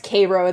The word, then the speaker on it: Cairo: It's pronounced Cairo, though, which is a whole thing in and of itself.